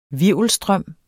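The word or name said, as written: HVIRVELSTRØM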